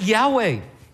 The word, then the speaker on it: Yahweh: We pronounce it Yahweh. Some people pronounce it Jehovah.